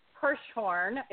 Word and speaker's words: Hirshhorn: We were at the Hirshhorn. That's how you pronounce it, right? – Right.